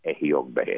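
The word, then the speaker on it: Ejiogbe: And so that sign is pronounced Ehiogbe, and that's the very first sign of Ifa.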